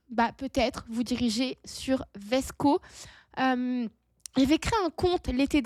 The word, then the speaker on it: VSCO: Il y a une autre application qui est sensiblement un peu comme Instagram, qui s'appelle Vesco. Ça s'écrit V-S-C-O.